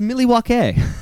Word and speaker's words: Milwaukee: Monday Night Raw, live from Milwaukee. – I believe it's pronounced Milwaukee. – That's right.